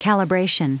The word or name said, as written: calibration